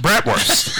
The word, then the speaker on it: bratwurst: Bratwurst. That's the correct pronunciation. – According to you. – According to me and all people who make bratwurst.